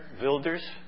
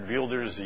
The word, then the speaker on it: Wilders: It's Geert Wilders. It looks like in English, W-I-L-D-E-R-S, Wilders, but it's pronounced Wilders.